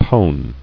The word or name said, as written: Pone